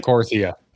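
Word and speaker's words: Korthia: You get the new zone, Corytha. Are we saying it right? – Corythia. – Okay, Corythia.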